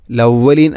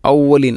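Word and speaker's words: الأولين: അസാതീറുൽ അവലീൻ, ലവലീൻ അല്ല ഔവലീൻ, ഹംസയെ കൊണ്ടാണ് പറയേണ്ടത്. അസാതീറുൽ അവലീൻ.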